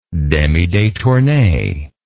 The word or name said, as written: demidetourne